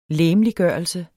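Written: LEGEMLIGGØRELSEN

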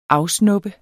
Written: AFSNUBBEDES

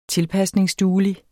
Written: tilpasningsduelig

